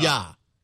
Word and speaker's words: Nougat: And you're pronouncing it Nougat. – Was it Nougat or was Nougat. It Nougat? I thought I heard Nougat. – Nougat. – Nougat. Nougat. Okay. All right. So there's no T.